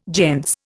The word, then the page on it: gentes: Indian